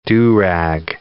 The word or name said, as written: do-rag